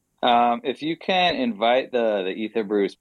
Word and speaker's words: Ether Brews: Ether Brews, cool. like Ethereum, Ether.